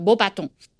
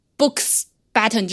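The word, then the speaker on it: Beauxbatons: 那么这个外国除了 Hogwarts 之外的另外两个国家的呃另外两个学校呢，一个是来自法国的 m o b a t o n 呃，请注意它的发音，它的正确的发音是 mo baton，就是美丽的魔杖。 OK，不是现在中文版里面翻译的布斯巴顿，因为没有 books button 这个没有这个发音，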